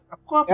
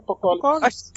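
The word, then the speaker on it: Apokolips: Gente, a pronúncia é essa? – Apocópolis. É Apocólipse.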